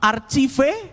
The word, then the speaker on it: archive: Ini bacanya archive kan, archive. – Archive? – Aduh. – Benar enggak? – Benar enggak? Benar enggak? – Benar enggak, teman-teman? – Salah. – Salah. – Yang benar apa dong? – Iya, benar sih. – Betul, archive. Ada sih tulisannya sih, benar sih. – Archive, yes. Oke.